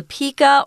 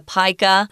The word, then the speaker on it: pika: Uh, Another animal that I didn't know existed called the pika or the pika. You can pronounce it either way.